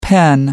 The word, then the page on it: pen: American English